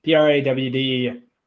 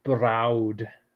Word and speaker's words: brawd: Brawd. Brood. – It's actually browed.